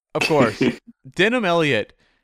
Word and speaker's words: Denholm: It's Denim. – Denim? Okay. Yeah. I think over here in North America, we've been pronouncing it wrong for a long time.